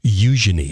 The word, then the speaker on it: Eugenie: I was just informed by one of our lovely listeners, and her husband is from London, that over in the UK, you know how you pronounce that princess's name? – Oh. – Eugenie. – You know, okay, Eugenie.